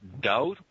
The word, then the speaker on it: doubt: People from the state pointed out that I never noticed before is the word doubt. Doubt? D-O-U-B-T? – Yeah, doubt. – We pronounce the T, and apparently we're not supposed to. Yeah, doubt, like no doubt.